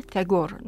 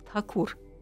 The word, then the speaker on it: Тхакур: Он родился в роду Хакуров, э, мы произносим на э, немножко искаженный английский э, манер Тегор. на самом деле он Рави Индра Над Тхакур.